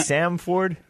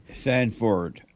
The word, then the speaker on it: Sanford: Is it Samford or Sanford? – Sanford. F-R-D. – Okay. Oh, yeah. There's no T there.